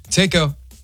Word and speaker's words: Taco: It's Taco with a T, yeah. – Taco.